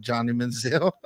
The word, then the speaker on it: Johnny Manziel: Like, you think about it, Johnny Football, Johnny Manziel, right? A lot of people didn't want to fool with him because of his. – I almost removed you from the live for calling Johnny Manziel.